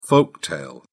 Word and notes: folk tale: British English

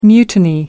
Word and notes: mutiny: American English

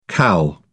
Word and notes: cal: British English

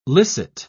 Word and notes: licit: British English

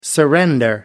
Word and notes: surrender: American English

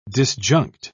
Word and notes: disjunct: British English

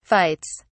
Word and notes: fights: British English

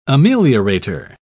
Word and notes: ameliorator: British English

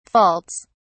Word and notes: faults: British English

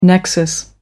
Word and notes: nexus: American English

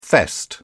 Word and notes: fest: British English

